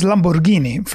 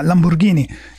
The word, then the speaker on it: Lamborghini: He just bought a Lamborghini boat, I believe. – Yeah, it's pronounced Lamborghini, first of all. – Oh, pardon me? – Yeah, Lamborghini.